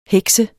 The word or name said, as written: heks